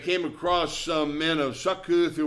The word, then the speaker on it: Succoth: They crossed over the Jordan to Succoth in Gad. I got a long O symbol in my Bible. Makes it a little harder to say Succoth.